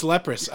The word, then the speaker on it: Leprous: Dude, they're from, Leprous, I think. – Oh, yeah. Leprous. – Oh, what did I say? Okay. It's Leprous.